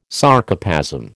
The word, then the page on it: Sarcoplasm: American English